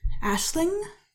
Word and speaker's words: Aisling: I believe it's pronounced Aisling. Aisling B. – Can I see? – It's an Irish spelling.